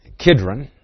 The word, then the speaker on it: Kidron: The brook Cedron, or Kidron, it's an Old Testament name. The Greeks thought it referred to cedars, and so they tended to pronounce it like cedars, the cedar book. But it doesn't mean cedars.